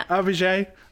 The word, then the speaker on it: AVIJÉ: And the first time we saw her name written down, we did actually ask her for a pronunciation guide. And she sent me a little voice note saying it's AVIJÉ.